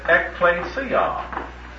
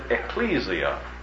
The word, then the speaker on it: ekklesia: The Greek word for church is the word ekklesia. Ekklesia, I'll spell it out for you, and most people who just transliterate it and spell it, they pronounce it ecclesia.